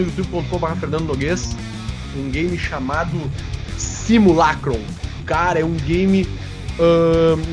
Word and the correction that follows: Simulacrum: É simulacrum, tá? – Que se, se fala, assim, né, como se escreve, né? Com C-R-U-M no final, tá?